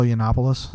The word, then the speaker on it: Yiannopoulos: Venalopoulos. – Yeah, the wounds haven't. – How you said that? – So how do you say his name again? – Milo Yiannopoulos.